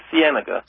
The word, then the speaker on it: Cienega: 908 North La Cienega, I think I got it right that. – Cienega. – Cienega Boulevard, that's spelled L-A, new word, Cienega, C-I-E-N-E-G-A Boulevard, Los Angeles, California.